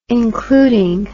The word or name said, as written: including